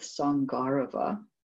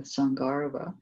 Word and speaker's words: Sangarava: So this text is called With Sangarava. That's how it's pronounced, because that long A will get the emphasis, With Sangarava.